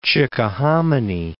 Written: Chickahominy

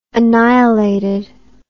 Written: annihilated

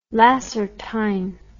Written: lacertine

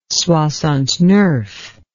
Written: soixante-neuf

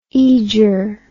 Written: aeger

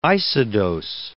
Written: isodose

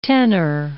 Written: tenner

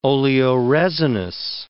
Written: oleoresinous

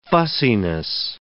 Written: fussiness

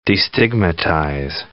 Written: destigmatize

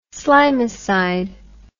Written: slimicide

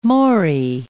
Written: morae

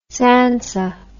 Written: tsantsa

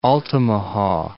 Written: Altamaha

